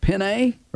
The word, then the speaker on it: penne: Penne or penny? Is it penny pasta or penne? – I've always said penny. – What's the fancy? – Okay. I've said it wrong always.